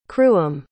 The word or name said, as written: cruim